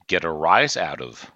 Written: get a rise out of

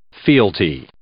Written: fealty

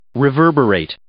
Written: reverberate

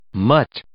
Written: mutt

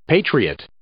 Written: patriot

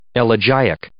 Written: elegiac

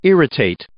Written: irritate

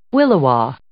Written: williwaw

